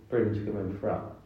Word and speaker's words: Brimscombe and Frome: Brimscombe and Frome.